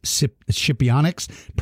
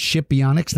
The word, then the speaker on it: Scipionyx: S-C-I-P-I-O-N-Y-X, Scipionyx, pronounced Scipionyx, not Scipionyx.